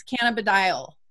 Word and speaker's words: cannabidiol: Carrie and I fought about how to pronounce that in the beginning, because I didn't like look up the pronunciation, and she's really good about that. So now it's like confusing. It's like, what the hell one is it? It's cannabidiol.